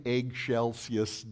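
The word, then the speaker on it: excelsis: And so he said, the way you pronounce it is eggshells. So in eggshellsius Deo, that way you always know how to sing it. It's an eggshell.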